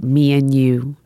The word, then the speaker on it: Mianu: It's spelled M-I-A-N-U. Uh-huh. And you pronounce it Me and You.